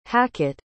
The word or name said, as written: hackett